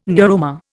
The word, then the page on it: neuroma: Indian